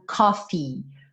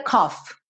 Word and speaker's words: coffee: Oh, I get up, novamente, get up, I get up and make myself some coffee. Coffee, ok? Esses dois E's no final pedem esse som. Então, nunca diga cough. Ok? Cough parece tosse. Cough, cough, cough. Então, coffee. Bota essa sílaba aí no final.